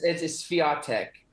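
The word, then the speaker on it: Świątek: Is it pronounced Swiatek? Now that I'm saying it correct, am I incorrect on that one? – It's Swiatek, and there's multiple pronunciations, but she's, you know, she says it's Swiatek for Americans, but it's in Polish, it sounds a lot different.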